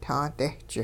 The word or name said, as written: taadehch